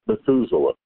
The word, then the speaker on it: Methuselah: The man's name is Methuselah. In English, they pronounce it Methuselah.